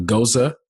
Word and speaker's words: Gose: So this beer is a it's a mashup of a Kolsch, a Goza, G O S E, looks like Goes, but it's pronounced Goza, and a Berliner Weiss.